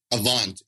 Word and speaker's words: Avant: Is it Avant? – Avant, yep.